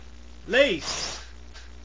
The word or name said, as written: lace